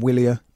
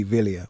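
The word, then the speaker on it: Wilier: I've been calling them Willia. I've heard you call them Willia as well, Daniel, in my defence here, but the pronunciation police have been on, and in Italian it would be Villia, wouldn't it? – Correct.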